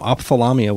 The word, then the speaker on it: Ophthalamia: This is off the Via Doloroso album from Ophthalamia, which is probably being pronounced wrong as well. – I think that's right. – Is it? – Yeah. – There's lots of H's in there, so I'm not sure.